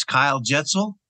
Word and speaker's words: Kyle Jetsel: It's Kyle Jetsel, and it's kind of like a pretzel with a J.